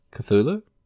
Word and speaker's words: Cthulhu: I have some people that we both know who pronounce it Cthulhu, and it annoys the fucking shit out of me every time. – There's a th in it. – Yeah. – Cthulhu. – Cthulhu. Not Cthulhu.